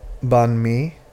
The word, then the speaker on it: banh mi: So, today, you've researched Banh Mi.